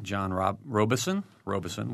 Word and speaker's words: Robeson: The Executive Director of Arts Illiana, John Robeson with a long O.